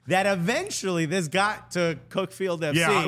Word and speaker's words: Cuckfield: I didn't know They pronounce it Cuckfield, but it's from Cuckfield.